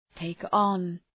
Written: take on

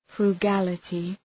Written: frugality

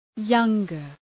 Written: younger

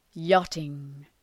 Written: yachting

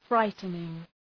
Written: frightening

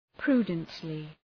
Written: prudently

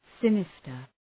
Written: sinister

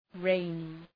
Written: rainy

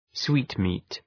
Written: sweetmeat

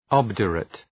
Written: obdurate

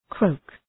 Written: croak

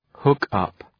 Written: hook up

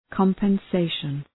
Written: compensation

